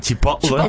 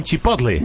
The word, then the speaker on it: Chipotle: Claro, no se dice Chipotle, se dice Chipotle.